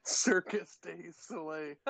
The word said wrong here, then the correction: Cirque du Soleil: Cirque du Soleil. Bud. – Cirque du Soleil. Thank you.